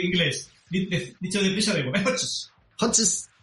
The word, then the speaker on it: Hodges: No sé si lo digo bien, ¿es Hodges o Hodges? ¿Cómo es? ¿Cómo sería? ¿Hodges? – Hodges. – Que te corrija. – Vale. – Hodges. Si lo dices deprisa, da igual.